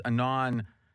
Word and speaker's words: Anand: Anand, Anand, yeah, is how you say.